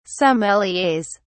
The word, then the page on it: sommeliers: British English